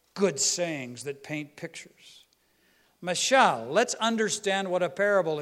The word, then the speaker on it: mashal: It is spelled M-A-S-H-A-L in phonetics. Say it in Hebrew, mashal. To be a parable, mashal.